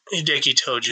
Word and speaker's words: Hideki Tojo: Hideo Tojomo. – Japanese? – Hideki Tojo? – Hideki Tojo. – Yeah, you fucking butchered that name.